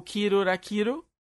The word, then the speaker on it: Kill la Kill: Porque é, Kill la Kill, que o japonês pronuncia como Kiru Rakiru, e isso é, é, é, é uma palavra que é homófona a algumas coisas diferentes.